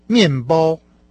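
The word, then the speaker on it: Мянбао (面包): Хлеб по-китайски «мянбао». – «Мянбао», «мянбао», «мянбао». – А ну-ка определите, с каким тоном произносится первый и второй компонент этого слова? – «Мянбао». Мянбао. Мянбао. – Правильно, четвертый и первый тоны.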